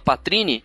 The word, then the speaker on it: poitrine: Vocês sabem o significado da palavra patrine? – Hum, vai, vai, que eu tô com medo já. – Patrine, a escrita é poitrine, que se escreve, que é do francês patrine, que significa seios femininos.